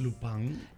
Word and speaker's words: Lupin: Porque se escribe Lupin, pero como es en francés. – Lupin. – Lupin. – Lupin, claro.